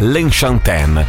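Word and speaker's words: Lenchantin: Allora, teoricamente la pronuncia del nome di questa artista dovrebbe essere L'Enchantaine, perché è un nome francese, anche se lei è argentina,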